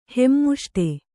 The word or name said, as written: ಹೆಮ್ಮುಷ್ಟೆ